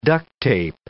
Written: duct tape

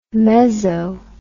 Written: meso

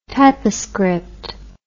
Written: typoscript

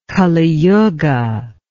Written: kali yuga

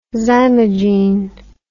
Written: zymogene

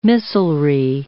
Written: missilery

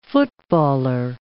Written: footballer

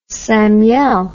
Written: samiel

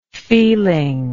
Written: feelingness

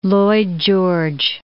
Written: Lloyd George

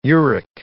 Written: uric

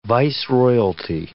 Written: viceroyalty

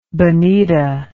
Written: bonita

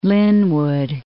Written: Lynnwood

